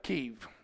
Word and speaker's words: Kyiv: And she lives in Kyiv, which is how the Ukrainians pronounce it.